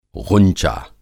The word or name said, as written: ग़ुंचा